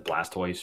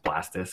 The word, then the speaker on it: Blastoise: It probably is, but like, I saw a TikTok of somebody that said Blastoise should be pronounced Blastus because it's tortoise.